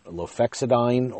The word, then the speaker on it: lofexidine: Lefexidine uh, lofexidine, lofexidine or lofexidine. I'll spell it for you. L O F E X I D I N E.